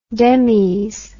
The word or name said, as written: demis